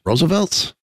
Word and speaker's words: RSVLTS: So, from RSVLTS, Roosevelt's, I guess it's how it's pronounced.